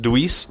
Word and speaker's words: Dewees: So yeah, we're uh, hanging at the Marquis with a uh, James Dewees.